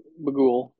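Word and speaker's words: Bughuul: What 2012 film stars Ethan Hawke as a true crime writer and featured a demon named Boogla? I think it's how you say it. Bugla. Bagul. Bagul. – Bagul. It is Sinister.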